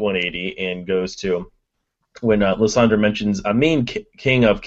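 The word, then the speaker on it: Lysandre: Lissandre? What's going on, Ariel? – Lissandra, I believe, is the French pronunciation, so...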